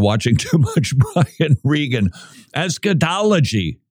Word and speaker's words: eschatology: You say, Friel, isn't that eschatology? I like to put the accent on the wrong syllable because then it makes it sound like I might have inside knowledge you don't, and that would make me seem smarter.